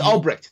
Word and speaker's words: Ulbricht: You still have a huge chunk of Americans who think Albrecht, am I saying his name right? Albrecht, the guy who started Silk Road? – Oh, Albrecht. – It's Albrecht. Thank you. Yes, yeah, U-L-B-R-I-C-H-T.